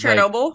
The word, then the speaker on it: Chernobyl: No, I'm just saying, because, Chernobyl? – Like, yeah, you're saying it right.